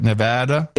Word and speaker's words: Nevada: Nevada? – Yep.